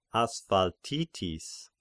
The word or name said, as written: Asphaltitis